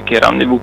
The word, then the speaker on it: rendezvous: Sì, esatto, ma intanto diciamo che il concetto a me poi piace dire rendezvous piuttosto che rendezvous, perché dalla, diciamo, versione, la pronuncia francese poi è diventato comunque un po' italianizzato, quindi rendezvous piuttosto che rendezvous.